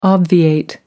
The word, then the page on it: obviate: American English